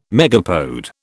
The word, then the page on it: megapode: British English